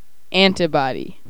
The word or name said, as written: antibody